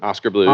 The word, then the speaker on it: Oskar Blues: Oskar Blues.